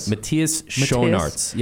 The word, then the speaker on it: Matthias Schoenaerts: Matthias Schoenaerts.